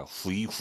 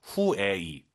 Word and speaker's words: 回: 자 그럼 집에 가다 후이 후이 하지 마시고요. UI 사이에 에 넣어서 후에이 들어볼게요.